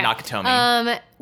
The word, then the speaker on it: Nakatomi: And he goes to Nakatomi Plaza. – In the film. – Am I saying that right? – Yes, yeah, okay. Nakatomi.